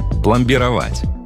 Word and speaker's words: пломбировать: Одно из них – пломбировать. Да, ударение в нем ставится именно так и никак иначе.